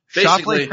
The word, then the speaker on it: Scheffler: Did I say that right? – Yeah, well, I think I did. – You said a lot of Shoffleys. – Scheffler finished 2nd.